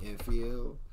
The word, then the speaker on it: Infiel: Infiel. I'm at El Perez. Y'all can find that joint on Spotify. Y'all spell it I-N-F-I-E-L.